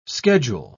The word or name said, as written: schedule